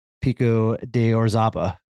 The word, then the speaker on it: Pico de Orizaba: And it is you and Nathan Longhurst attempting to do the first ever international infinity loop down at Pico de Orizaba.